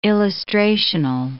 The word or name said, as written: illustrational